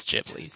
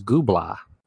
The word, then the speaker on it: Ghibli: I actually don't think there's a right way to pronounce it, because technically the Italian word it's taken from would be Ghibli, and the Japanese pronunciation, like the official Japanese pronunciation, is Ghibli. So there's a wrong answer, though, which is what I'm going to start saying, which is Ghibla, Studio Ghibla.